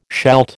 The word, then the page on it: shalt: American English